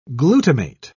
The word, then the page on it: glutamate: British English